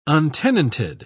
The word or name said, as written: untenanted